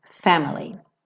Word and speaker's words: family: Family é uma palavra que tradicionalmente tem a sílaba tônica. Tem como sílaba tônica a primeira, né? Family. Mas por conta da métrica da música, ela fala God's great big family. Então acaba ficando family. Mas se você estiver só falando, family.